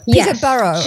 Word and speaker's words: Peterborough: In fact, Petersburg, Ontario. Is that correct? – Peterborough. – Just okay. – A bit north of Toronto. – Very good. Okay, thank you for correcting my pronunciation there.